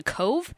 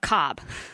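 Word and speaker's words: Cobh: And so then the next morning we headed to, which we found out we were actually pronouncing it wrong. It's actually Cove. It's not Cobb.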